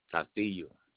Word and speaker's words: Castillo: J-U-A-N. – Can you verify your last name? – Castillo. C-A-S-T-I-L-L-O.